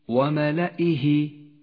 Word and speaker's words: وملئه: لاحظ كيف كتبت كلمة وملئه، وملئه. لاحظ هذه الياء الرمادية، ميزها لك مصحف التجويد بهذا اللون الرمادي إشارة إلى أنها لا تلفظ، وهي من زيادات الرسم العثماني.